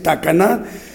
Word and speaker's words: Tacaná: Bueno, Asunción Tacaná, eh, bueno, una disculpa, lo hemos pronunciado como Tacana, pero no es Tacaná, el acento la tiene en la última, es Tacaná, en San Marcos, en Guatemala.